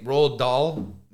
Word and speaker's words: Roald Dahl: Roald Dahl, is that how you pronounce his name? – Yeah.